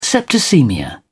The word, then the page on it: septicaemia: British English